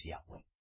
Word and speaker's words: Yahweh: Miren, sobre YHWH, vuestros líderes mesiánicos de pacotilla o judíos mesiánicos no les han enseñado que la pronunciación es Yahweh. Que la orden de nuestro creador,